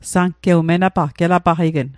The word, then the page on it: menapaqq: Mi'gmaq